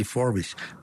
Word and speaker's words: Forbes: Sí, sí, Forbes.